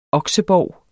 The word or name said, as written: oksebov